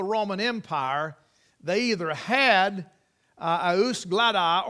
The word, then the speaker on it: jus gladii: If I were to say, eus gladi, eus gladi, they would know what that was. In English, it's spelled J-U-S, G-L-A-D-I-I.